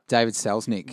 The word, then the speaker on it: Selznick: So, legendary film producer David Selznick offered... am I pronouncing that right? – David Selznick.